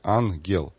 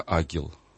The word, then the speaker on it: ангел: Ангелы, это да, в славянской традиции, поскольку, ну, по греческому праву писанию, если стоят две гаммы подряд, то первая читается как Н, а вторая как Г. Ангел. А традиция славянская сохранила такое интересное свойство, что если речь идет о добром ангеле, то читается правильно ангел. А если о бесе, то тогда агел. Агел. То есть Г читается дважды.